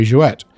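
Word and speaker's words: Jouët: We were saying Jouet earlier. Let me address that. There's an umlaut over the E. You have to pronounce the T.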